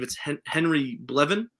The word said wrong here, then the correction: Belvin: Yeah. – Belvin. Henry Belvin the fourth. – Belvin, okay.